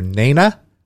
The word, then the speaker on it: Nena: We got your Falco, we got your Nena. Did I say that right? – I think you did.